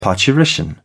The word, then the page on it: parturition: British English